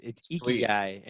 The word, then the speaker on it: Ikigai: And today we are talking from the greater Boston area, sales leader, mentor, coach, and self-proclaimed guy with Ikigai. Did I pronounce that right? – You pretty much nailed it. Yeah, it's Ikigai.